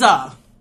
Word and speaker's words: SZA: It's SZA. – No, you're fucking not. It's SZA.